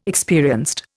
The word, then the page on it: experienced: Indian